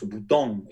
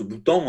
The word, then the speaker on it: Boutang: Cela dit, en français euh, euh, standard et donc en français d'Île-de-France, bien sûr, on prononce boutant, et on ne fait pas sonner le, G final. Et on prononce boutant en nasalisant.